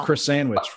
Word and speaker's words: croissant: It's pronounced croissant.